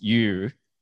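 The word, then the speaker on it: yu (鱼): Because fish is pronounced yu, and there's a saying, nian nian you yu.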